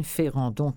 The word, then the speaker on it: Ferrandon: Alors, la corde à linge de Max Ferrandon, je vous l'appelle F-E-R-A-D-R-A-N, pardon, D-O-N, Ferrandon, comme ça se prononce.